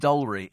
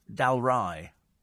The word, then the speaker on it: Dalry: It's not pronounced Dalry, it's Dalry, says Ian. Yeah, whatever. Dalry. Well, that's stupid. Why don't they spell it D-A-L-R-Y-E?